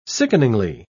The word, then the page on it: sickeningly: British English